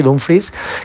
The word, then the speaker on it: Dumfries: creo que ha pedido que le llamemos así, Dumfries, casi como se escribe prácticamente,